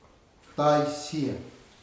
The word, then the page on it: tai sia: futuna aniwa